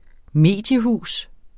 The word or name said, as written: mediehus